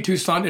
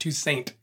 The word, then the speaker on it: Toussaint: It's actually it should be Toussaint in French, but in Haitian Creole, they pronounce it Toussaint.